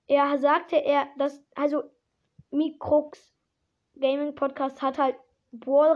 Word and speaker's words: Microx: Und jetzt noch die zweite Info und zwar an Mikros Gaming Podcast. Ich glaube, der Name wird so ausgesprochen. Irgendwie guckt nochmal jetzt auf Spotify. Mikrox, Mikrox Gaming Podcast, ja.